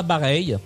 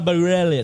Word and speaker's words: Bareilles: Alors, moi, je le prononce Sarah Bareilles, mais il paraît qu'il faut dire Sarah Bareilles.